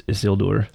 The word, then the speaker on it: Isildur: It's Isildur. – I like the way I'm saying it better. – We got told that you're supposed to put the emphasis on the second syllable for these Numenorean names.